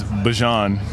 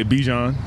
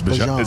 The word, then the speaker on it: Bijan: How you pronounce my name is Bijan. For everybody that's out there, I know a lot of people say Bijan, but it's Bijan. It's Bijan, yeah.